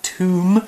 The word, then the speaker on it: tomb: Okay, well, we also have the word tomb, another tricky word to spell because of a silent letter, T-O-M-B.